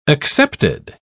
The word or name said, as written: accepted